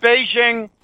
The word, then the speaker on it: Beijing: The second not-so-easy one is that when Chiang Kai-shek abandoned and escaped with his life from, back then it was called Peking, Peking is the Cantonese pronunciation, and Beijing is the Mandarin pronunciation.